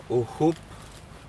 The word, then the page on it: uhup: Aneityum